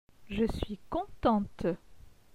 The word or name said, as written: Je suis contente